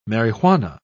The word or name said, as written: marijuana